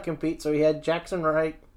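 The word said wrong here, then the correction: Ryker: Jackson Riker had his replacement against Hardy.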